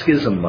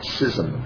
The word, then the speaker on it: schism: It's not schism, by the way. It's schism is the way it's pronounced.